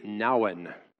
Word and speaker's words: Nouwen: There's a thoughtful Catholic Christian writer of a few years ago named Henri Nouwen. His little memoirs were called Now and Then, so that's, you know, chuckle, chuckle. At least you know how to pronounce his name that way.